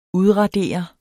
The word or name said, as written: udradere